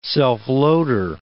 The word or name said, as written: self-loader